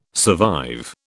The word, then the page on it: survive: British English